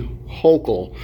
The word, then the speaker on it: Hochul: They say, I was reading online that her last name rhymes with local, so I'm going to assume her name is Kathy Hochul or Hochul, whatever.